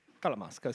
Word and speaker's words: Talamasca: Talamasca, giusto? Ho detto bene? – Talamasca, sì, sì.